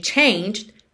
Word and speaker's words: changed: O E de changed não é pronunciado. E o D é uma consoante oclusiva que vem só fechando esse som e pode aparecer mais, menos ou nada.